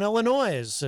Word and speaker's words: Illinois: It's like John Hopwood saying Illinois. It's like, no, you don't pronounce the S, and he refuses to believe that, but it is a fact. You do not pronounce the S.